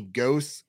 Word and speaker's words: Gose: Anthony Gose, I think I'm pronouncing that right, G-O-S-E. – I believe that's correct.